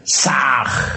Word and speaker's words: Sah: Ham escribe con las letras H-A-M. Sah se escribe con las letras S-A-H. La H suena siempre como J.